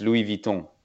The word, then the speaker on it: Louis Vuitton: Well, it's actually in French. It's Louis Vuitton. – Oh, yeah, you want to know that's the right way to pronounce it.